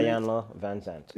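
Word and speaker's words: Iyanla Vanzant: That's how you say it? – Ayanna Van Zant.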